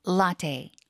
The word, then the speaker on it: latte: Matcha latte. – Latte. – Yeah. – 이 라떼보다 영어로 읽을 때는 라테에 가깝죠. 테이. – 네네네. – 약간 테이.